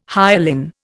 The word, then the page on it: hyalin: Indian